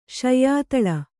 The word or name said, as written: ಶಯ್ಯಾತಳ